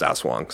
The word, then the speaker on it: Aswang: I was calling it Ass Wang. It sounds a little funnier, but I mean, no disrespect. Words are fun. They're funny. But it's pronounced Ass Wang.